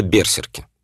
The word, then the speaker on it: берсерк: Это берсерки. По-русски иногда говорят берсерки, ну, древнескандинавское ударение на первый слог, я поэтому тоже говорю берсерк.